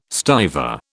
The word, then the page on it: stiver: British English